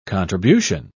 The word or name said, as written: contribution